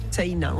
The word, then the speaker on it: Seina: Ça s'écrit un peu comme la Seine, mais... – Voilà, avec un A à la fin, Seina. On prononce le I aussi. – Comme si on avait un tréma au-dessus.